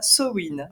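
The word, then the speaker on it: Samhain: On va le prononcer à l'irlandaise et ce sera Sowin.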